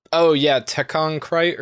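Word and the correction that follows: Tekkon Kinkreet: Krite, it's Tekkon Kinkrete.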